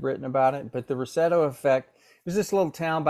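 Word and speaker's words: Roseto: So you talked a little bit about, is it pronounced the Rosetto effect? – Rosetto.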